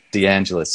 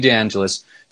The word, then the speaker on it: DeAngelis: DeAngelis. So Jeannie DeAngelis.